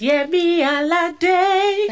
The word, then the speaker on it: Yemi Alade: I want you to say it how you say it in the songs. It's Yemi Alade.